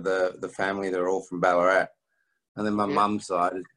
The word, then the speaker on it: Ballarat: So that was called Ballarat, if I pronounced that correctly. – That's right, yeah. Funny accent, but you pronounced it correctly, yeah.